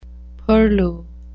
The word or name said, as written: purlieu